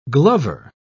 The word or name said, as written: glover